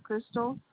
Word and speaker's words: Crystal: Crystal, CRYSTAL.